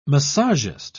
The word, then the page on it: massagist: British English